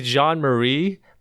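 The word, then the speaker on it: John Marie: My name is Jean Marie, but it's spelled J-O-H-N and Marie. So my parents wanted to be like Jean-Marie after the priest. – Oh, yeah. – But they didn't spell it J-E-A-N. But they didn't spell it the way they should spell it with J-E-A-N. They thought it would be cool. It's called Jean-Marie.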